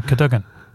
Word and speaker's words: Cadogan: So how you say it then, Cadogan? – That's the only way I've heard it pronounced. – Well, I've never heard it pronounced, so it's news to me. I'm happy to. It looks a bit like cardigan, so I thought that was going to be the emphasis.